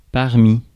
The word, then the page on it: parmi: France; /paʁ.mi/